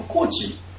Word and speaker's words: Kocs: このコーチの語源はですね、このハンガリーの KOCS と書いてコーチと読むらしいんですけども、そのコーチという町で作られた滅場所の名前、それが KOCSI、コーチっていうふうに、読むんですけども、に由来しているそうなんですね。それが英語圏で、スペルが変わって COACH のコーチになったっていうことなんですね。